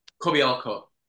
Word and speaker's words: Kobialko: Kobialko.